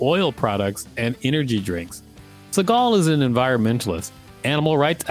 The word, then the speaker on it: Seagal: Why did you start calling him Siegel? It's Seagal. – But look at how his name is spelled. – Yeah, but we've been saying Steven Seagal the whole time, and then in the last sentence you changed his pronunciation to Siegel.